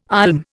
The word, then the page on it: olm: Indian